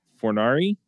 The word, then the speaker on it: Fornari: We also have Caleb Fornari.